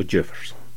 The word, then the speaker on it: Jefferson: Idaho, Oregon o Jefferson. Estas tres palabras que se acentúan en la primera sílaba en su pronunciación